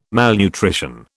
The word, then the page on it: malnutrition: British English